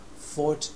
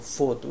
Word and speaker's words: fought: 次 5 番 Fought with police、この Fought はファイトの過去形過去分詞ということは、警察と戦ったということです。これも fought というふうに発音してくれればいいんですが、今回のスピーカーは fought というちょっとこう O の音が弱いんですよね。